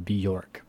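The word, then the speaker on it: Björk: We pronounce this Bjork, by the way.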